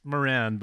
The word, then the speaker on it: Moran: It's Moran, but I was just in Ireland. They say Moran there, which always feels kind of weird, like going back to the place where the name comes from and saying it wrong.